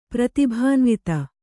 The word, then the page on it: ಪ್ರತಿಭಾನ್ವಿತ: prati bhānvita